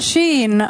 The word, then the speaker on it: Shein: Quindi io su Shein, che poi Shein. – No, si pronuncia Shein.